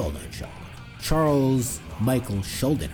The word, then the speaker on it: Schuldiner: That's how you pronounce his name. Schuldiner. We should never pronounce his name right. We used to call him Chuck Schullinger. Like, we couldn't read him. When we were like teenagers, like, oh, the guy from Death, Chuck Schullinger.